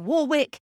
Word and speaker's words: Warwick: I can tell you that Brits do not call Dionne Warwick Dionne Warwick. Warwick here is pronounced Warwick.